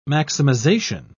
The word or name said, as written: maximization